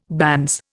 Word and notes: banns: Indian

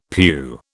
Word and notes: pew: British English

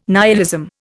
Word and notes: nihilism: Indian

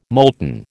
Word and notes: molten: American English

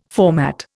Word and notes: format: Indian

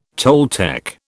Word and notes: Toltec: British English

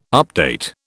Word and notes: update: British English

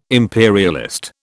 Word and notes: imperialist: British English

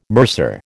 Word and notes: mercer: American English

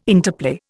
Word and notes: interplay: Indian

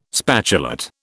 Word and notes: spatulate: British English

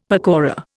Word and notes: pakora: Indian

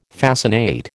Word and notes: fascinate: American English

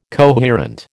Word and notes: coherent: American English

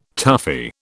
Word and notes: toughie: British English

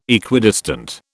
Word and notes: Equidistant: British English

